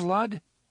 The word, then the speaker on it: Lud: "Have you heard of Lud?" Eddie asked. "Perhaps," the gunslinger said, "when I was very small." "It rhymes with crud," Eddie said.